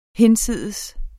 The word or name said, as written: hinsides